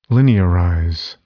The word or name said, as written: linearize